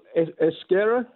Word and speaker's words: Euskera: Yeah, so Euskera, is how you say it, is it's a fascinating language.